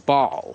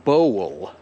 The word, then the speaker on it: bowl: I wiem, że czasami ludzie niepoprawnie myślą, że to się wymawia tak jak ball. Że piłka jest ball, ale miska jest bowl. Że to B-O-W to jest bow. I potem jak dodamy L, to jest bowl, bowl.